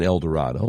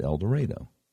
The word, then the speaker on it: El Dorado: Now, if you're outside Arkansas, that's how we pronounce it here. We don't pronounce it El Dorado like in Texas. We pronounce it El Dorado.